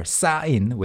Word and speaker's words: 사인: Or 사인 with 더블시옷, 쌍시옷. So the pronunciation is one, 사인. – 사인. – The strong S sound.